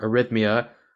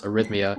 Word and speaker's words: arrhythmia: You talked about this, but I know you were diagnosed with heart arrhythmia. I don't know much about what that is, so if you want to talk about it, feel free, because I spent 15 minutes trying to pronounce arrhythmia.